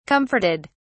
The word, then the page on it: comforted: British English